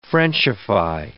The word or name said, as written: frenchify